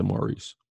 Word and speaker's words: Maurice: Listen, my name is Maurice. It's a French name. I pronounce it Maurice.